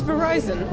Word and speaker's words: Verizon: It's Verizon. – Well, I know. I always say Verizon. Verizon. Oh. – Like, but it rhymes with Horizon.